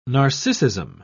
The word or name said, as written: narcissism